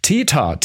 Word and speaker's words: Theta: Täter, T-H-E-T-A. Täter.